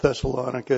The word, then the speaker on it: Thessalonica: It's kind of like kilometer, which is should be kilometer, but let me assure you that Thessalonica is correct. I think the emphasis is not on the I syllable.